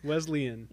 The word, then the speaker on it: Wesleyan: Did I say it right? – Close. Well, yeah. – So, no, you didn't, but you did. – You know, you were close. You're better. – Wesleyan? – Wesleyan. – Wesleyan. – Yes.